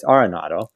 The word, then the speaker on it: Arenado: So I'm happy to know that I know how to pronounce his name correctly, and it's Arenado.